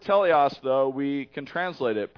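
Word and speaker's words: teleos (τέλειος): We were mispronouncing it, and he's like, guys, it's teleos.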